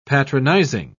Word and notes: patronising: British English

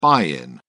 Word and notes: buy in: British English

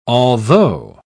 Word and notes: altho: British English

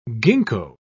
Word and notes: ginkgo: British English

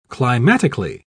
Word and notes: climatically: British English

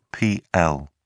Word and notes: pl: British English

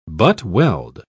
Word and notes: butt-weld: British English

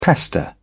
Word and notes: pester: British English

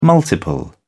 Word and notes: multiple: British English

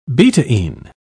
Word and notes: betaine: British English

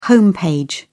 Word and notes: home page: British English

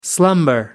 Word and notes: slumber: American English

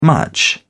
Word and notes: much: British English

